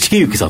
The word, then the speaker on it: 成行: この成り行きって、なんですけど、茂幸さんって読むんですけど、はいはい、で、実は証券マンにとって成り行きって大好きなんですよね。